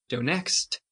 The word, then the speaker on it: Donetsk: Donetsk.